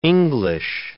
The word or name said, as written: English